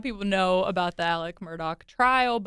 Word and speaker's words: Murdaugh: Murdaugh trial.